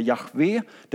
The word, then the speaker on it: Jahve: För de upplevde att Guds namn, alltså JHVH, eller troligtvis uttalas det Jahve, det var så heligt så man ville inte säga det, utan man sa Adonai, som betyder Herre.